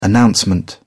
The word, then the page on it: announcement: British English